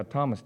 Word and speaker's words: Thoma: Both of them, which by the way, I don't guess I told you how Thomas died. Thomas died. He was in India. That's where he's very famous in it. Thoma is how they say his name.